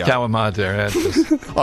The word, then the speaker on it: calamari: Calamari. – Oh, so you know that? Oh, that's the Italian pronunciation.